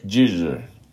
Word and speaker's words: Giger: Giger. – Giger. It's German. No, it's Swiss. – G-I-G-E-R.